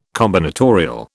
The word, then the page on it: combinatorial: British English